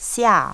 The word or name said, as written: xia